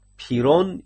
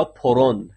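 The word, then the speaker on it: Pyrrho: بنیانگذار این مکتب شکگرایی رو اگر به عقب برگردیم، سال آقای پیرون یا پرون، هر دو تلفظ هست، که در سال 270 قبل از میلاد مسیح متولد شده، در سال 360 هم از دنیا رفته.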